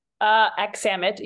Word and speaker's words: Aksamit: And did I say your name right? – Aksamit, you were close.